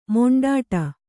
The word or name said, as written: ಮಿಂಡಾಡು